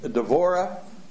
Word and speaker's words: Devorah: The Jews pronounced Deborah with a V. Devorah is how they would...